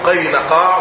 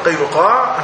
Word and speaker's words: قينقاع: وقينقاع مثلثة تنطق قينقاع وقينقاع وقينقاع بالثلاثة إجازة، وهم بنو قينقاع. هؤلاء طائفة من طوائف اليهود الثلاث، عندنا بني النضير، بني قينقاع، وكذلك أيضا بني قريظة.